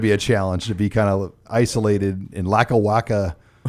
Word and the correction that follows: Lackawanna: Pennsylvania. Did I say that right? – Oh, Lackawanna. This is Lackawanna.